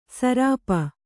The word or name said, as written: ಸುರೂಪೆ